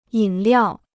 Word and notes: 饮料: yǐn liào

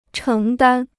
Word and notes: 承担: chéng dān

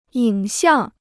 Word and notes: 影像: yǐng xiàng

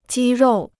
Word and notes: 肌肉: jī ròu

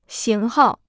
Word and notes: 型号: xíng hào

